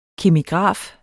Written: kemigraf

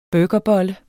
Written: burgerbolle

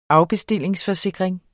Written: AFBESTILLINGSFORSIKRING

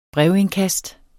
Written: BREVINDKAST

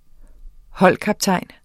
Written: holdkaptajn

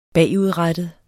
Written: bagudrettet

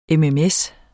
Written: mms

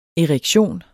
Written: EREKTIONENS

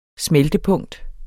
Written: smeltepunkt

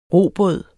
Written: robåd

